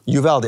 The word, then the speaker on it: Uvalde: Uvalde, okay, great.